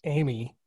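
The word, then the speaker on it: AMi: The jukebox named capital A, capital M, lowercase I, which is pronounced Amy, is Quentin Tarantino's own.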